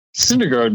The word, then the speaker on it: Syndergaard: So, Noah Snidegard. Am I pronouncing that right? You're in New York. Am I pronouncing that right? – For a sports show, you're all over the MLB beat, bro. Syndergaard, man.